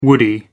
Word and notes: woody: American English

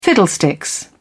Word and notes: fiddlesticks: British English

